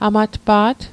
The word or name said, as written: amatpa't